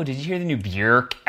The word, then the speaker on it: Björk: Her name is not pronounced Björk. I think it's Björk or something. Rhymes with work.